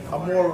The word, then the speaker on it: amore: how do you pronounce the name? Amore. Amore. That's it's amore.